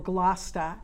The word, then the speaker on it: Gloucester: Gloucester, or Glosta, as I explained to her we pronounce it.